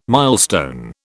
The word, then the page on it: milestone: British English